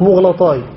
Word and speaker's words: مغلطاي: وقهد قال مغلطاي، هلا أدين مغلطاي هكذا تنطق مغلطاي بضم الميم، قال مغلطاي قهد لقب لعمرو الذي وجد يحيى ابن سعيد الأنصاري.